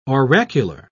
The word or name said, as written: oracular